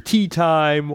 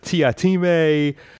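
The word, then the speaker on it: Teatime: So continuing with a bit of the story from where we left off last time, the assassin Mr. Tea Time, or Mr. Tiatime, depending how you pronounce it, his plot to eradicate the belief in Hogfather in order to assassinate the Hogfather, that plot is continuing.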